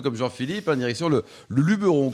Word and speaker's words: Luberon: Et donc on dit Luberon quand on, prononce d'une façon locale, même s'il y a beaucoup de gens qui disent, moi, je m'envie dans le Luberon, mais.